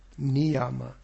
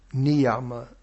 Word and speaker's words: Niyama: Niyama. Here the Y is in the middle, so it's pronounced as a Y. Niyama.